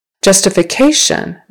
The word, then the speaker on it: justification: Justification. Now it's the third syllable stress.